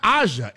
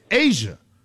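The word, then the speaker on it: A'ja: You're saying it's not Aja, it's Asia.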